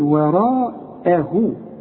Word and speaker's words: وراءه: أعلمك إزاي تنطق وراءه إزاي، ورأى را، مد شفايفك على هيئة قبلة، والمد بتاعها كمان مفخم، وراء رجع شفايفك على هيئة ابتسامة، وراءه،